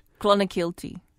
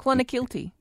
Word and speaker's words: Clonakilty: Clonakilty. – I'm sorry, what? – Clonakilty.